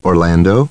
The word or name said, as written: Orlando